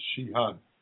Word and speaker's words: Sheehan: My name in Chinese is Sheehan. – Oh, it's Sheehan. Okay, that's how you pronounce it.